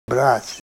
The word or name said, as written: brat'